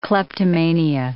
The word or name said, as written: kleptomania